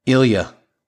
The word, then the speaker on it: Ilya: Ilya. – Ilya.